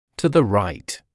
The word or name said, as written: to the right